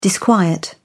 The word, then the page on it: disquiet: British English